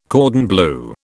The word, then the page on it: cordon bleu: British English